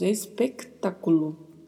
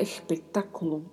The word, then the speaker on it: espectáculo: Atenção aí para as pronúncias, né? Dos S também mais fechados, com som de E. E da letra C, que vem antes da letra T. Então, falamos espectáculo. É a pronúncia correta.